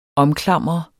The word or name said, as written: omklamre